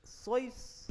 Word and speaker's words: Seuss: Seuss. – But because he was writing during post-war, people started pronouncing it as Seuss, and it was a more Americanized version than a real German name.